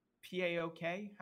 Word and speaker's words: PAOK: Uh, you have Marseille, Marseille uh, P A O K. How do you say? You just say Payok, right? Don't you? – Paok, um, Paok, yeah. Yeah, that, that's where Christos uh, Tzolis came from.